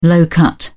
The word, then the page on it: low-cut: British English